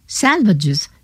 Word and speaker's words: Salvages: Salvages se pronuncia de manera que rime con azuages, dice T.S. Eliot.